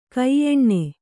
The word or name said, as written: ಗಾಯನಿ